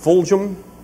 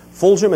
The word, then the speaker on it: Fulghum: A fellow named Robert, I think his name is pronounced Fulghum. I've given some of his books to some people I thought might be interested in seeing these things. Fulghum, is that the way you pronounce it? F-U-L-G-H-U-M.